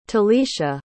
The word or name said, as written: talisha